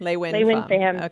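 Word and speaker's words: LeUyen: Lewin is how she pronounces Lewin. It. – Lewin Pham.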